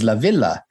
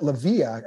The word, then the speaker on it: La Villa: Is it La Villa or is it La Via? – I think the correct pronunciation in Italian is La Villa.